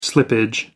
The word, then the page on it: slippage: American English